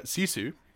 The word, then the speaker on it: Sisu: I believe I'm pronouncing this right, Sisu. Um, I'm really hoping I'm pronouncing that right. – I think you are, yeah.